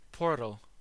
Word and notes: portal: American English

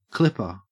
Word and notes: clipper: Australian English

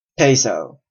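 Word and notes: peso: Canadian English

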